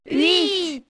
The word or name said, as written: huit